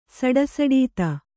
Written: ಸಡಸಡೀತ

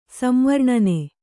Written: ಸಂವರ್ಣನೆ

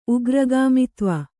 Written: ಉಗ್ರಗಾಮಿತ್ವ

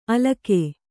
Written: ಅಲಕಿ

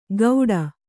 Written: ಗೌಡ

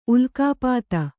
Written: ಉಲ್ಕಾಪಾತ

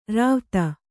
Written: ರಾವ್ತ

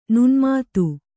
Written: ನುಣ್ಮಾತು

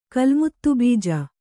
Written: ಕಲ್ಮುತ್ತುಬೀಜ

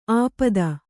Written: ಆಪದ

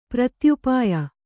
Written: ಪ್ರತ್ಯುಪಾಯ

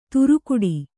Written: ತುರುಕುಡಿ

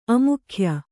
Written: ಅಮುಖ್ಯ

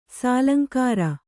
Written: ಸಾಲಂಕಾರ

